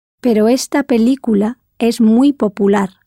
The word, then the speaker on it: película: As you know, the word for movie or film in Spanish is película, spelled P E L I with an accent, C U L A.